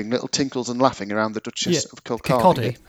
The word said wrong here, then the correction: Kirkcaldy: Kirkcaldy is how you pronounce it. It's spelt Kirkcaldy, but as my Scottish partner would tell you, it's Kirkcaldy.